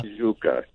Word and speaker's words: Tijuca: Tijuca.